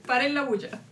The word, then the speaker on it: Para la Bulla: c'est Para la Bullienne, je le prononcerai très mal parce que moi, je ne parle pas du tout espagnol. – Para la Bulla. – Para la Bullienne, ok, donc rien à voir.